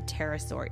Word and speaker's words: pterosaur: The crazy thing about the pterosaur is that there is a big old P right at the very front of the word, but you don't pronounce it.